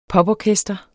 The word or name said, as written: poporkester